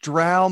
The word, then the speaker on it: drow: It's pronounced drow, motherfucker.